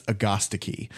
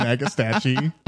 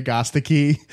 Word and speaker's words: agastache: So yeah, technically it's agostiki. And hmm. so once I no found, thanks. Once I found out that that was the correct pronunciation, I just call it hyssop now.